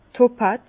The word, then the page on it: topaz: Bas-Rhin; Herrlisheim